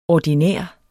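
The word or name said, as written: ordinær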